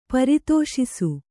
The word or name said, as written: ಪರಿತೋಷಿಸು